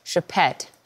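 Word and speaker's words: Choupette: And even though the most anticipated guest, the designer's famous cat, Chepette, how'd I do? Right pronunciation? All right, good.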